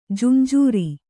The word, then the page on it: ಜುಂಜೂರಿ: junjūri